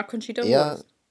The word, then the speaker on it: Conchita Wurst: Conchita Wurst. Sprich, spricht man. – Ja, Conchita Wurst. Eher so.